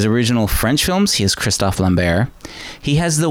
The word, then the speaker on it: Christophe Lambert: I kind of clued in when you said Christophe Lambert. I was like, what? That's how you say his name? I had no idea. – Yeah, for all these years, I've been saying Christopher Lambert.